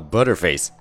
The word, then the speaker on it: butterface: Butterface 这个词并不是说这个女的脸长得像黄油一样，那其实这个词是很有意思的，它其实是 but her face 的缩写的形式。如果你不信，那你就由慢到快的来读一下这三个词：but her face，butterface，butterface，butterface，right？那你看是不是最后快读就读成了 butterface？